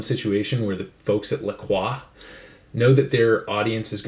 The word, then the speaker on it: La Croix: First, we have a can of unflavored La Croix, or some people know it as La Croix. That'd be the American pronunciation. I'm half French-Canadian, so I have to say it. La Croix.